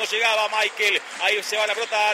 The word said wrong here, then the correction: Miquel: ¿Cómo le decimos? ¿Michael o Miquel? Nicolás Álvarez, usted que es especialista en nombre. Se escribe Miquel. ¿Cómo le digo? – Decirle Miquel. – Entonces le digo a Miquel, usted me enseña y yo aprendo.